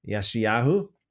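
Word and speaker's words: Yeshayahu (ישעיהו): For those of you who don't know, that's Isaiah's Hebrew name, or that would be the correct pronunciation, actually. Yeshayahu.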